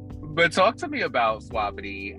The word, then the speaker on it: Suavity: So, talk to me about the creation of Suavity. Am I saying that right? – One. You are saying it wonderfully. Yes, it is Suavity. You got it.